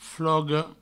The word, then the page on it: foga: fogo; Provençal